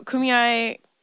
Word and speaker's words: Kumeyaay: I mean, growing up here, knowing a little bit about Kumeyaay. Am I saying it right? – You are. – Okay. About Kumeyaay indigenous people and their land and all of that.